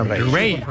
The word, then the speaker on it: Raye: Ray. Ray. Se escribe Raye.